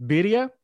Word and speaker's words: birria: When you forgive me if I mispronounce it, Birria. – That's fine.